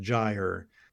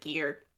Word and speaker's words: Gyre: That spelling is a real life word pronounced Gyre. And so, gosh darn it, authors, don't do that. – It rhymes with tire, which is also spelled similarly with. – I mean, Gyre also rhymes with tire, but it's a soft G.